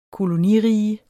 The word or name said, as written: kolonirige